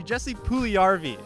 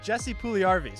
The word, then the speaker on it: Jesse Puljujärvi: Jesse Puljujärvi is the last name.